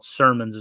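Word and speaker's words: Sermon: Packers running back coach Ben Sermons – I'm sorry, I said Simmons. I think it's called Sermons is how you pronounce it.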